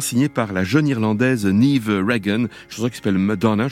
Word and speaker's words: Niamh: J'ai vérifié, alors parce que ça s'écrit N-I-A-M-H, donc, mais ça se prononce pas du tout Niamh, ça se prononce, allez savoir pourquoi, Niamh.